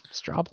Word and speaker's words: Straubel: But J.B. Straubel, is that how you say his name? Straubel. – That's how I say it.